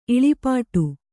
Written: ಇಳಿಪಾಟು

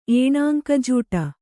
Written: ಏಣಾಂಕಜೂಟ

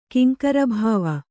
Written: ಕಿಂಕರಭಾವ

